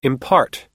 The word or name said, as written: impart